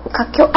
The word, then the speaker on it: 華僑: 華僑というのはどういうことかちょっと説明してもらえますか。 – 華僑、あ、発音が間違えましたか。 – 華僑です。